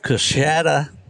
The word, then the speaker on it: Coushatta: I've got Colby Self of Coushatta, Louisiana.